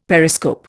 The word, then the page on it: periscope: Indian